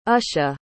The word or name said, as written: usha